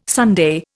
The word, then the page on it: sundae: Indian